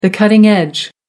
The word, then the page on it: cutting-edge: American English